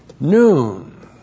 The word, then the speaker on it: nun: And it's followed by another adverb, noun. That's N-U-N, noun.